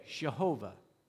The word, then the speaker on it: Jehovah: So in the English, we pronounce it Jehovah with a J, Jehovah.